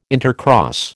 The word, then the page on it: intercross: American English